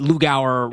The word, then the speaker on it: Lou Gower: And that helps because the Lou Gower rhymes with hours.